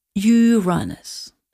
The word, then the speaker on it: Uranus: Did you know, by the way, that there's apparently a new pronunciation for Uranus to avoid little jokes? – So what is it now? – Uranus.